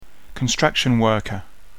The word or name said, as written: construction worker